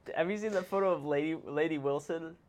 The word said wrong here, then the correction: Lainey Wilson: Am I saying that right? – Lainey Wilson? – Isn't she the country singer?